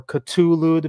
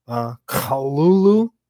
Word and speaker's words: Cthulhu: I don't know how deep into the Cthulhu or Cthulhu, depending on your pronunciation. If you're a real Lovecraftian, it would be more like Kalulu.